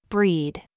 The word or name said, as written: BOB